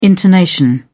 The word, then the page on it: intonation: British English